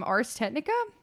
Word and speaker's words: Ars Technica: But this article is from Ars Technica. I think I'm saying that right. – Yep, you are.